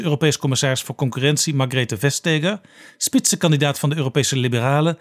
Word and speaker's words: Vestager: Maar haar naam spreek je net iets anders uit. We would say Margrethe Vestager. Vestager. Ja, yeah.